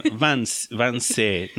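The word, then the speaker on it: Wannsee: Se organizó una conferencia en la región de Vanesse, o sea, se escribe W. – De Vanessens. – Sí, no, no Vanesse, perdón, Vanse,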